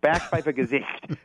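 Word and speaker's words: Backpfeifengesicht: they have a word called Backpfeifergesicht. – Mm-hmm. How do you spell that? – It's Backpfeifergesicht. You're not speaking German right unless you're spitting. Backpfeifergesicht.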